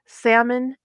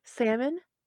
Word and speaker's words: salmon: And I was like, salmon croquettes. And he was like, what? I was like, salmon croquettes. And he was like, do you mean salmon patties? So I was like, the L is silent.